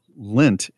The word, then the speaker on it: Lindt: Uh, lint, lent. How is the name pronounced? L I N D T. – D T. Right? Lint.